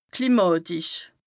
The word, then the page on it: klimàtisch: Bas Rhin